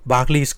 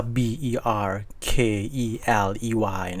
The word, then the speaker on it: Berkeley: B E R K E L E Y นะครับซึ่งชวนให้เราอ่านว่า Berkeley แต่ว่าจริงๆแล้วเนี่ยคนลอนดอนอ่า